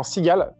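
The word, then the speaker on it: Seagale: Euh, je, suis, je suis le fondateur, cofondateur de la marque de vêtements Sigal, qui s'écrit S-E-A-G-A-L-E, e, depuis, depuis 5 ans maintenant.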